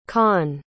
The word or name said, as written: kahn